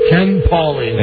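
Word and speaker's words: Ken Pauli: Ken Paulie. Any relation to Jane? – No, it's spelled like St. Paulie girl. – Oh. – With an I. – It's like Paulie. – P-A-U-L-I? – Yeah.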